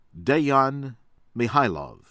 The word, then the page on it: Dejan Mihajlov: American English